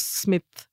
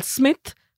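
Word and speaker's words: Psmith: They're just about a lovable scamp called Smith, whose name is spelled with a P at the beginning,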